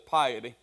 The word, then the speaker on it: piety: And some people may pronounce it piety. I looked it up. It's actually a long I, so it's piety.